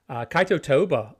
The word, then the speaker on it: Kaito Toba: Kaito Toba on the podium in third.